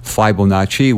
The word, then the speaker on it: Fibonacci: And now I want you to talk a little bit about your research and what you've discovered, because the way, and is it pronounced Fibonacci? – Yes, Fibonacci.